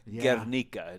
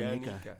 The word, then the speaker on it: Guernica: Guernica, it's pronounced. – Yeah. Guernica.